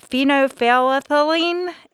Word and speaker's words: phenolphthalein: The first test that they did was, I think I'm probably going to butcher the pronunciation of this, but phenolphthalein, is that right? – Mm-hmm.